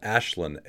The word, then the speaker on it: Aislinn: Ashlyn, A-I-S-L-I-N-N, that's how you spell it.